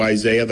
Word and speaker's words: Isaias: We're here at the new Division of Emergency Management Emergency Operations Center with preparations undergoing for now Hurricane Isaias. The pronunciation is a little bit weird. I've told people if they want to do Isaiah, that's fine with me.